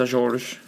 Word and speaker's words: Azores: And you didn't say it right, though. It's Azores.